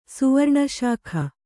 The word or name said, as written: ಸುವರ್ಣಶಾಖ